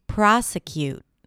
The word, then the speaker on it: prosecute: Okay, the next word is prosecute. Prosecute. The primary stress here is on the first syllable, pra, pra, pra.